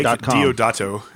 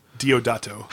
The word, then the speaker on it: Deodato: But I took Guardians of Nowhere 4 by Brian Michael Bendis and Mike DiPardo. Mike DiDato. – Mike Diodato. – Mike what? – Diodato. – How the hell do you get Diodato from that crap? – That's spelled D-E-O-D-A-T-O.